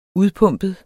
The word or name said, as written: udpumpet